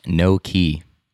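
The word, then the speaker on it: gnocchi: By the way, the soup is pronounced no key. – Oh, it's no key? – I think.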